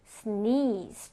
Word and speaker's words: sneeze: 자, 이가 두 개예요. 그래서 sneeze. 좀 길게 발음해 줘야 됩니다.